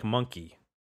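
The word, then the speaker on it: Mwnci: Monkey is a spelling of the word monkey using Welsh spelling rules. Rather than a direct Welsh translation, it is pronounced like monkey.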